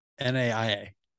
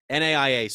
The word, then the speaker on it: NAIA: NAIA. – NAIA school.